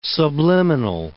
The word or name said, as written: subliminal